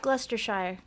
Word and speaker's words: Gloucestershire: Local councils will sometimes close the roads, and then there is the Gloucester, which is an acronym for, I don't know how to say the name of this place. It's Gloucestershire? – Gloucestershire. Gloucestershire. Gloucestershire, yeah.